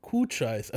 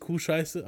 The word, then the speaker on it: Cochise: Aber hier jetzt auch Kuhscheiß, also nicht Kuhscheiße, aber C-O-C-H-I-S-E. Also, aber du, ausgesprochen heißt der Kuhscheiß.